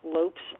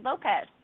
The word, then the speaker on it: Lopes: Oh, see, we did say it wrong. We said Lopez instead of Lopes. Okay. – Lopes, yeah. – Correct. – Yeah, I'm Cape Verdean, not Spanish.